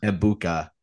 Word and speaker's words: Egbuka: I mean they do have Marvin Harrison Jr. and Mbuke. Well, did I say his name right? – Emeka Egbuka. – Egbuka.